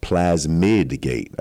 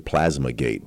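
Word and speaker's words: plasmidgate: And by the way, I, I want to correct my pronunciation. Plasmid gate. I think I might have said plasma gate, but again, this is not my wheelhouse.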